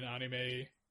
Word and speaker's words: anime: The last experience I have with a live-action adaptation of an anime, do I pronounce it correctly, anime or manga? – Anime.